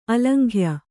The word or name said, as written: ಆಲಿಂಗ್ಯ